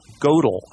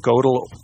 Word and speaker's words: Gödel: Yeah, here it is by Kurt Gödel, I believe is how you pronounce his name. Gödel, mm, yeah, I guess that's how you. It's got the umlauts above the two dots above the O, so right. I, I think that's Gödel.